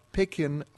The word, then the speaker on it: Pekin: She's the director of Pekin Fine.